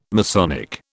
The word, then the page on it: Masonic: British English